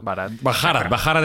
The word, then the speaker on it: Bharat: Bharat es como ellos lo pronuncian.